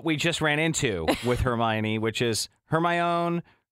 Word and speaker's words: Hermione: But I guess, how do you say her name again? Hermione. – Mm-hmm.